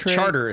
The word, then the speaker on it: Chartres: No, that's Charter is how they pronounce it down there.